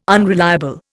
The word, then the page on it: unreliable: Indian